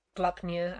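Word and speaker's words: Gleipnir: Glipnir. Glupnia, actually, how it's pronounced.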